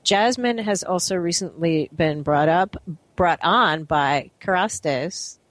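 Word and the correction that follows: Kérastase: Did I pronounce that right? Jasmine, just come in before I continue your introduction. – Hi there. It's actually pronounced Kerastase. – Kerastase.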